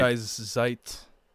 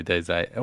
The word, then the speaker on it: Die Zeit: But he could only get a German publisher, Die Zeit. Did I say that right? – Die Zeit. – Yeah, Die Zeit.